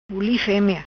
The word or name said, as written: Buli Femea